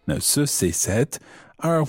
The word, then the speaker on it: cette: Now, the feminine version of this is cette. And it's spelled C-E-double-T-E, cette.